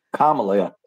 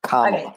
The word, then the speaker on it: Kamala: Kamala, yeah. I think the first syllable is the emphasis. Kamala.